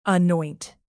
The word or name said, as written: anoint